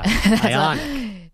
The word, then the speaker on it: IONIQ: Yeah, IONIQ. – You put the emphasis on the wrong syllable. – Yeah, it's not Dominique Wilkins. It's IONIQ.